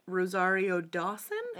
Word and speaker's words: Rosario Dawson: And his ex-girlfriend, Rosario Dawson.